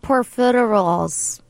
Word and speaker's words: profiteroles: Wait, what about profiteroles? I don't know how to say it. – You said it exactly right.